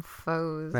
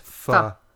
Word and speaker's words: pho: I think I used enemies this time because I didn't want to get them mixed up with the soup, which you told me is actually pronounced pho. But I always say foe. So in my mind, it's foe.